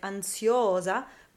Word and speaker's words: ansiosa: So, when the stress of the syllable in a word like ansiosa goes to that o, then we use the i as a j glide.